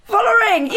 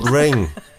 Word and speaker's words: Vollering: It rhymes with mall ring. – Yes. – Salting. – No. – Vollering. – Yes. – Demi Vollering. – My God. – Vol ring. Demi Vollering. – Vollering.